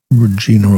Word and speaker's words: Regina: First name is spelled R-E-G-I-N-A. I think it's Regina, rather than the other pronunciation that rhymes with a female personal part.